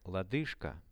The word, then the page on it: lodyzhka: Russian